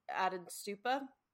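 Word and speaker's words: Ättestupa: even just like the Atenstupa. Okay, I did say it. I said it right.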